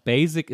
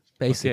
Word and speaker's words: basic: das ist natürlich, das ist eher ein schärferes S und nicht ein weiches S. Basic, okay. sagt man, nicht Basic. Okay. – Basic.